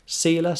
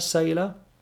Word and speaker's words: Selah: Selah, Selah, can be pronounced both ways.